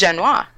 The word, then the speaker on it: Jeunet: I'm going with Jean-Pierre Jeunet. I'm saying that wrong probably, but Delicatessen. – Yes, Jeunet, thank you.